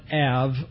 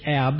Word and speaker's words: Av: All of this happened on the 10th of the Hebrew month Av, or as we pronounce it sometimes in English, Ab, A-B. The Hebrew is A-V.